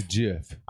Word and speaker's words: GIF: J-I-F-F-Y. – Okay. All right. So how do you spell GIF? – There is a hard G and a soft G. Let's not go there. – Okay. But isn't the soft G decorated? Is it not accessorized? – Accessorized with what? This ain't Russia. We don't put some sort of. We don't put tildes over G's. GIF.